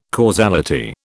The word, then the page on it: causality: British English